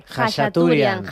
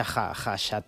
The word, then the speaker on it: Khachaturian: Khachaturian. – Khacha. – Aram, se escribe K-H-A, pero se pronuncia Khachaturian.